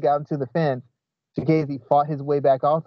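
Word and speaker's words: Chikadze: He kept getting uh how do you say his last name? Uh Chikazi? Uh oops. – Chikazi. Chik yes, like Chikazi.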